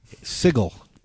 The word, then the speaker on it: Sigil: Sigil is actually how it's pronounced. Because sigil is a word in English.